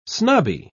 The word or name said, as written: snobby